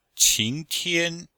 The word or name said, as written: qing2tian1